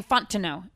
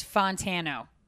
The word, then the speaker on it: Fontenot: Emily Fontano. And I'm like, no. It's Fontano. – Emphasis on tan. – Kind of. – Emphasis on the tan.